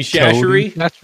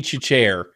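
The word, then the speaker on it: Chachere: I spent my whole life pronouncing that like Tony Chichere. And then I knew that wasn't it.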